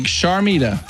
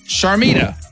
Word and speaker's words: Sharmita: Like Sharmita. I'm saying it correctly. Because yep. – I usually butcher names because I don't hear well. Sharmita.